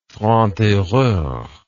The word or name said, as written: franc tireur